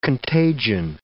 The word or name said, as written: contagion